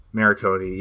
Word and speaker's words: Morricone: Morricone, yeah, that guy.